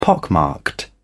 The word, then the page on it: pockmarked: British English